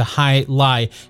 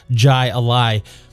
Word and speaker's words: jai alai: Thanks so much for the additional insight into high lie and for taking the time to write in, Jeff. I think throughout the entire episode of Black Mass, I mispronounced that as Jai Alai.